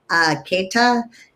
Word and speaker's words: Caytha: It's spelled K-A-E-T-H-E, Caytha, and so they were like, my mom was Carol, my dad was Chester. So they're like, oh, let's spell it with the C.